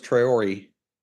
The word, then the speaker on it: Traore: But Jagusa is the correct pronunciation, and so is Traore. – Let's go back to the offensive line. Kali 1-2-3.